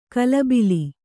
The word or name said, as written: ಗಲಬಿಲಿ